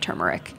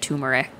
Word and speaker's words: turmeric: great that you can now buy like 5 varieties of turmeric. Mm-hmm. At Whole Foods. What bothers me is, well, one, when people pronounce it turmeric, I'm just like, there's an R there. It's turmeric.